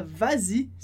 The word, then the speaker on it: vas-y: Então, no caso do y, quando a gente fala vas-y especificamente, a gente acrescenta um s para que a pronúncia fique mais fácil.